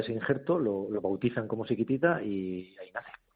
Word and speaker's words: Sikitita: Siquitita como la canción de ABBA. – Sí, bueno, no sé si irá por ahí o... Pero bueno, no, porque se escribe distinto, se escribe S-I-K-I-T-I-T-A, ¿no? – Con K de kilo, correcto, eso es.